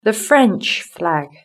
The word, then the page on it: the French flag: British English